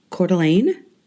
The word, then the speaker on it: Coeur d'Alene: So most recently, I was invited to go to Coeur d'Alene, Idaho.